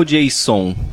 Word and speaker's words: JSON: Qual a pronúncia certa? JSON ou Jason? Jason. Jason, tá.